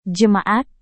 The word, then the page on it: jemaat: Indonesian